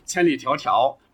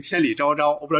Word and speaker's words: 千里迢迢: 比如说，就是袁泉他们来了哈，都都来听课来了，他当着那些人就直接教孩子念那个千里迢迢，直接念成千里昭昭。我不知道这个你们看没看过哈，就是他用浓重的这个西北的口音，千里找找。